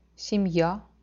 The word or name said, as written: semija